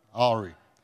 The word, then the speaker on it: awry: How do you say that? Awry? Awry. Awry. There you go.